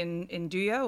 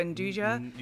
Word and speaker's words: Nduja: I say Nduja or Nduja. – You can say both.